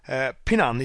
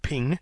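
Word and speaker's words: Ping (平): Pinan is written using two characters. The first character is pronounced Ping in Chinese, specifically Mandarin, Pin by the Okinawans, and He in Japanese.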